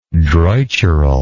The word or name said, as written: droitural